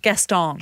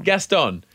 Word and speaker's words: Gaston: Gaston. – Gaston.